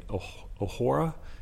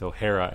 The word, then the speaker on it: O'Hara: Tulli continues to say O'Hora, and he corrects him with O'Hara.